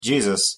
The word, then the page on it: jesus: English